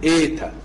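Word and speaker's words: ايتا (ήτα): الحرف اللي بعد كده حرف ملوش مقابل لا في الانجليزي ولا في العربي، حرف بيتنطق ايتا، ميلة ايه.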